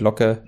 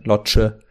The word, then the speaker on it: Locke: Sachen wie mit äh, Jessys Familie, die man kennenlernt, dann das war mit der Kirsche obendrauf, mit Locke, Lotsche, wie die Deutschen toll aussprechen.